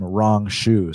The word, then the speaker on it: Rong Zhu: Uh, look it up. That's actually how you pronounce his name. Like that's literally. Ask Anik. Anik is the pronunciation king. You pronounce this guy's name rong zhu.